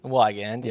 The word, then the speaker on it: Weigand: Weigand.